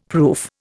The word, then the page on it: proof: Indian